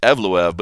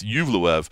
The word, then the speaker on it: Evloev: Talking about those rookie reports, Mosar Yuvlev, I think is the right way to say it. Yuvlev. I was saying Evloev, but it's Yuvlev.